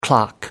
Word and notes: clock out: American English

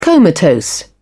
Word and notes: comatose: British English

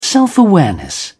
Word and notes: self-awareness: British English

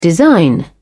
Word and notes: design: British English

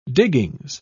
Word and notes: diggings: British English

